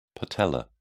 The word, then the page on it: patellar: British English